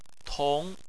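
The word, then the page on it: tong: tone 2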